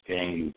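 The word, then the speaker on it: Danyeil: You can find me on Facebook as Daniel Green, but you got to spell it with a Y.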